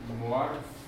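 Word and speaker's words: μορφή: Morphe mit der Betonung noch drüber.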